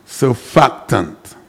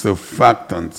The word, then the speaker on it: surfactant: All right, the name of the word is pronounced surfactant. S-U-R-F-A-C-T-A-N-T, surfactant.